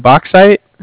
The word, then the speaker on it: Bauxite: Is it Bauxite? – Exactly.